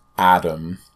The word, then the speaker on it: Adam: I always, not always, but if I'm introducing myself to someone and I say, I'm Adam, some people think I say Matt. Because it's like the I'm Matt, but they don't hear the last syllable, and this is like Matt.